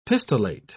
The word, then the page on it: pistillate: British English